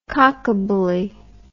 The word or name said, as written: cockabully